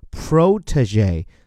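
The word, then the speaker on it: protégé: 而如果是美音呢，后面部分不变，而前面这个 o 字母发作 o，叫做 protege。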